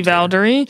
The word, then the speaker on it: Valdary: Valdary.